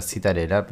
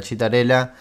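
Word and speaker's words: Citarella: Eh, yo me, me, enteré, este festival que se pronuncia de esa manera, pensé que era Citarela, pero bueno, es Laura Chitarela.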